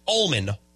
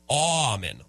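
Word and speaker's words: Auman: A cool stat yesterday from Greg Aumann, I believe it's pronounced. Not Allman, Aumann, like an all that's a tool, I believe, that is an all...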